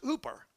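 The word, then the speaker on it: hooper (ὑπέρ): Well, it's spelt the same way, but it's actually pronounced in the Greek language, pronounced hooper.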